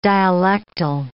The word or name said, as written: dialectal